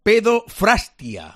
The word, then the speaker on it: Pedofrastia: Pedofrastria, a ver si lo he dicho bien. – Sí. – No, Pedro, he puesto ahí una letra, una R más. Pedofrastia.